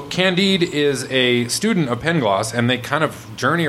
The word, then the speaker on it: Pangloss: So Candide is a student of a character in the book called Pangloss. Is that how you say it? – I don't know. – Yes, Pangloss.